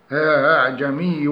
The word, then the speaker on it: أعجمي: الحروف الفرعية هي التي تخرج من مخرجين وتتردد بين حرفين أو بين صفتين، وهي 1 الهمزة المسهلة وهي التي تنطق بين الهمزة المحققة والألف، ولا يوجد في القرآن همزة مسهلة إلا الهمزة الثانية في أعجمي من قوله تعالى أعجمي وعربي في سورة فصلت آية 44، وتخرج ما بين أقصى الحلق والجوف.